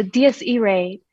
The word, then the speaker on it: Dies Irae: Oh, Dies Irae is the album.